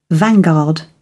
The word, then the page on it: vanguard: British English